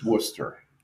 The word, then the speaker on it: Worcester: It's not like people, actively go to, Worcester because they're like, and I use that Worcester because I want to make sure I'm getting the Boston pronunciation. – No. You're actually saying it wrong. It's Worcester. – Worcester. Thank you.